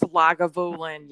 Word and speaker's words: Lagavulin: And they put the ice cube in there, and I can't pronounce it. You can pronounce it. It's that Lagavulin, whatever, from Isla. – That's Lagavulin. Yes.